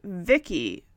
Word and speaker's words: VICI: The storylines revolve around Vicky, V-I-C-I, an acronym for Voice Input Child Identikit, pronounced Vicky, V-I-C-K-I, an android in the form of a 10-year-old girl.